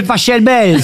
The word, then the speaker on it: Pachelbel: Cannelloni, c'est Pachelbel. – S'il est allemand, ça se dit Pachelbel.